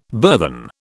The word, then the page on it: burthen: British English